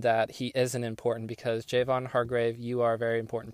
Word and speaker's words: Javon Hargrave: Now, I butchered his name so bad. And I realized it, too, as I was saying it. I was like, ooh. So let's just do Javon Hargrave right here.